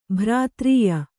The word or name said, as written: ಭ್ರಾತ್ರೀಯ